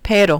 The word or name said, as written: pero